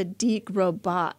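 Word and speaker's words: de Grosbois: Close. It's DeGrosbois. – Oh, Grosbois. Okay, we got a little bit of the French in there. So, Teresa DeGrosbois.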